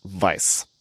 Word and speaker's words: Weiss: And I am pronouncing his name, specifically his last name, in the German way, because he was born in what is today Germany. And his last name means white, the color white. However, I'm pronouncing it the German way because it's spelled W-E-I-S-S. And German, a W is pronounced like a V.